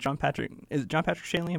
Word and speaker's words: John Patrick Shanley: Well, it's almost like, I love it because it's almost like John Patrick Shanley? Am I yes. – saying that right?